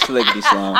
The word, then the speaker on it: Sliggity Slime: It's Sliggity Slime.